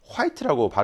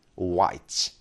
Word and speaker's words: white: 자, 그리고 이 WH 발음을 우리가 이제 화이트라고 발음을 하시는데, 실제로 우리가 듣는 발음에 미국식 발음이 훨씬 많거든요. 네. 미국식 발음에서는 white. H 발음이 나지 않습니다.